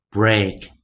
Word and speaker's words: break: break 不要念成 break 啊，是 break break break 好，所以有的时候我们了解发音啊，就在 e a 的时候，有时候啊，在 e a k 这个地方，在长元的时候有不同的念法啊。